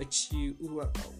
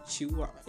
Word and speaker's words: chihuahua: Oh my god, a chihuahua. Is it chihuahua? Don't break my teeth. Like, seriously, let me just spell it again. C-H-I-H-U-A-H-U-A.